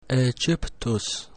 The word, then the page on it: Aegyptus: Latin